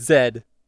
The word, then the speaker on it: zed: A, B, C, D, E, F, G, H, I, J, K, L, M, N, O, Z is Zed? – Q, R, S, T, U, V, W, X, Y, and... – Zed. – Why? – That's the correct way to say it.